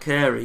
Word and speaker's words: Caere: Um, Akaser? Kaer? Is that. How do you say that word? At Caere, yeah.